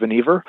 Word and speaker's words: Vannevar: It's spelled Vannevar, but it's pronounced Vannevar.